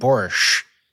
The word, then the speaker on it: Boris: You said Borish. Is that the Yeah, well, I'm sorry. I wanted to distinguish from Epstein. And so I was kind of, I was maybe leaning a little too much into the sh sound there. – Oh, no, I think that's probably the native pronunciation.